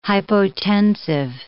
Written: hypotensive

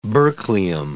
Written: berkelium

